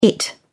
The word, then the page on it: it: British English